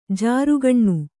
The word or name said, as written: ಜರಕ್ಕನೆ